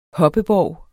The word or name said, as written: HOPPEBORGE